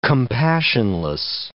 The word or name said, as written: compassionless